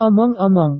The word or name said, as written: omong-omong